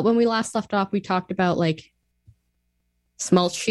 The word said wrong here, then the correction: schmaltz: How do you say this word? – Schmaltz. – Schmaltz. – Yeah, it's a sh sound.